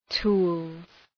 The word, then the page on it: tools: tu:lz